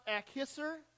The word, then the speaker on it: Akhisar: And if you have your map with you, and if you're a geography person, Thyatira is modern day, I think it's pronounced Akhisar, Turkey. If you look it up on the map, you'll see what it's about.